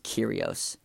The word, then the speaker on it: Kyrgios: That's how, okay, so also his, the spell, I just remember this, the spelling, oh, excuse me, I'm sorry, the spelling of his name is Kyrgios, but it's pronounced Kyrgios. So the G is silent.